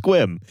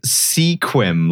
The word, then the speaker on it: Sequim: It's spelled like sequim, like a sequimed dress might be. And then minute one of joining the call, I was assaulted with the knowledge that it's pronounced squim.